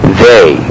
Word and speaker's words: they: That is, if I give you a long A sound in English, for instance, in the pronoun T-H-E-Y, I would naturally, as anyone would, pronounce it they. Now notice that that sound of the vowel is not pure. If I stretch it out for you, it sounds like they.